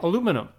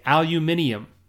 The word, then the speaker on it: aluminum: In the US, I, we would say aluminum. If you go to England, they say aluminium. Okay? Same word, different pronunciation.